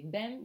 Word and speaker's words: bem: Even though it's spelled B-E-M, we don't say bem, we say bem. It sounds more like an N-G.